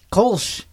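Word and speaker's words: Kölsch: Kolsch. – Kolsch. – Kolsch, yes. – Kolsch, yes. It's a gluten-free beer because I'm gluten-free. – A gluten-free Kolsch. – Mm-hmm. – Interesting. Interesting thing about Kolsch was first brewed in Cologne, Germany.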